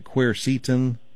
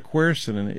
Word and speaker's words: quercetin: That's the way I pronounce it. I've heard some people pronounce it quercetin. That's okay, too. I don't mind. But there's an analog, which means the biggest part of quercetin is a flavonoid called rutin. R-U-T-I-N.